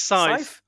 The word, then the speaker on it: Scythe: Sky, I don't know if I'm pronouncing that right. Scythe? – I think it's Scythe.